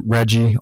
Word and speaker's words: Reggie: My name is spelled Reggie, R-E-G-G-I-E, last name Ordonez, O-R-D-O-N-E-Z.